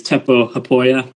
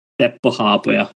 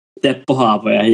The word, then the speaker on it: Teppo Haapoja: So the English way is Teppo Haapoja, but the Finnish way is Teppo Haapoja. – Teppo Haapoja. – Teppo Haapoja, yeah, but no one says it that way, so we just go with Teppo.